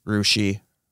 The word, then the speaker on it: Russia: Rushi, Russia. – They call it Rushi? – Yes.